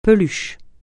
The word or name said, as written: peluche